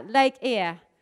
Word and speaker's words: Eyre: I wanted to show you quickly Lake Eyre. I said that right.